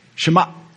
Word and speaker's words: Shema: Shema, close your throat right there. Shut it off. Shema. That's how you say it.